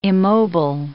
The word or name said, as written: immobile